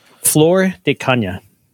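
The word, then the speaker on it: Flor de Caña: Flor de Cana. – F-L-O-R-D-C-A-N-Y-A.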